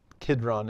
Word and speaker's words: Kidron: Kidron is how it's often pronounced elsewhere.